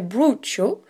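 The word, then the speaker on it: brocciu: Alors il y a plusieurs prononciations et c'est vrai qu'on avait du mal à se faire comprendre là-bas puisque alors la prononciation correcte apparemment est broccio.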